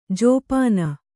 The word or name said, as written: ಜಪ್ಪನೆ